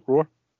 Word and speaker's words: Roar: Is that how it's spelled, Roar? – Yep, R-O-A-R, Roar.